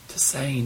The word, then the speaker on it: tisane: What, how do you say that? – Tisane? – Tisane.